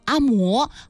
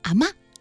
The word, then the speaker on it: 阿嬷: 对，既然当地读作阿嬷，希望各位在读这部电影的名字的时候也不要读成阿嬷，而应该读成阿嬷。